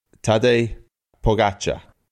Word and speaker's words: Tadej Pogacar: It's Tadej Pogacar. – Okay. Tadej Pogacar.